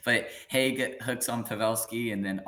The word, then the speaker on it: Hague: Um, Nicolas, how do you say that? Hague gets a hooking call against Joe Hague. Pavelski. – Hague, sorry. Hague.